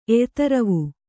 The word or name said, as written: ಎಯ್ತರವು